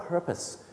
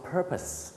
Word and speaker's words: purpose: คำว่า purpose ออกเสียงว่า purpose ไม่ใช่ purpose นะครับ